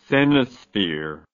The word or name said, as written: cenosphere